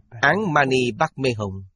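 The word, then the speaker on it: Án Ma Ni Bát Mê Hồng: Phát âm chính là Án Mani Bát Mê Hồng. Đây là cách phát âm của Tây Tạng.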